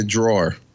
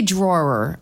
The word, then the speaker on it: drawer: I keep it in the drawer. – Okay. So you say it right. You say drawer. I have a hard time with that. I say draw.